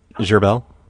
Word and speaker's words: Gerbil: Gerbil. – Can you spell that? – G-E-R-B-I-L. – B-I-L.